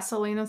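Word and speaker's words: Celaena Sardothien: So if you're listening, I think I would play an amazing main character would be an amazing, how do you say her name in the first book, Selena? – Yeah, Selena Sardothian.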